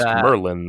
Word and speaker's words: Merlin: I think it's pronounced Merlin though. Because the DD in Welsh is an L.